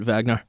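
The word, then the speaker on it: Wagner: Well, it's like Vagnar. It's a W, but they pronounce it Vagnar.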